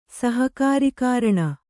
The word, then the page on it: ಸಹಕಾರಿಕಾರಣ: kannada